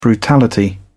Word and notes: brutality: British English

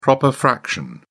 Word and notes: proper fraction: British English

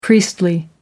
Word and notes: priestly: American English

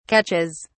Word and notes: catches: British English

